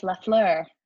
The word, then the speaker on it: Lafleur: It's the flower in French, Lafleur.